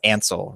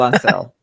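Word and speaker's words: Ancel: It's pronounced Ansel, right? – Michael Bay. – Not Michael Bay. Michel Ansel.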